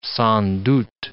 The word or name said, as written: sans doute